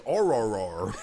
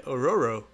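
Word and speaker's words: Ororo: I mean, you always say her name, but don't really until you read it as Aurora. – What? What are you talking about? – Yeah. What the hell? – Yeah, it's O-R-O-R-O. – Yeah, Aurora. – Aurora.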